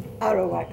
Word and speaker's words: Arawak: And I think I'm right in saying she comes from the Arawak clan. Is that correct? – Arawak.